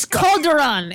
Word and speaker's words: Calderon: It's Calderon. It's not that hard.